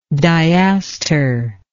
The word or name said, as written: diastral